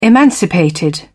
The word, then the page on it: emancipated: British English